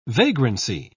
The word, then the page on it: vagrancy: British English